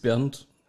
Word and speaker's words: Bernd: Mein Name ist Bernd. Wie bezaubernd.